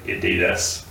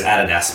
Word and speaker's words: Adidas: And now he has been cancelled, because, like, last week he was like, I can say the most anti-Semitic stuff ever, and I don't know why Americans say Adidas. – Adidas? Yeah. Oh, I hate that. Yeah. It's Adidas, motherfucker.